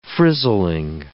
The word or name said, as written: frizzling